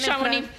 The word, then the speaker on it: Chamonix: Primera edición en 1924, concretamente en Chamonix. No sé si lo he pronunciado bien. – Chamonix. – Chamonix, gracias, en Francia.